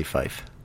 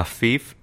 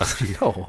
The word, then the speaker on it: fief: So, I mean, it's obviously fife. – A fief is a fief. No. It's fife. – A feudal estate. – Does it give a pronunciation? – It does, and it says fee, F-E-E. Fief.